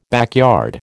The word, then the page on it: backyard: American English